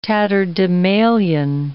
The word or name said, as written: tatterdemalion